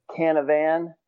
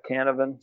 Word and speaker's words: Canavan: But yes, if I order pizza, I'll like overpronounce it. Canavan. if it's just people that I know with a certain degree of familiarity, it's usually Scott Canavan. So either way, lots of A's in there.